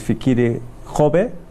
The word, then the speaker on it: Fikile Kgobe: Joining me now in studio is Fikile Kgobe.